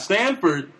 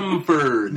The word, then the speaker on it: Samford: Yeah, not Stanford. U of L Samford. – Now. – Pronounce that M.